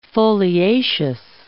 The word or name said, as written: foliaceous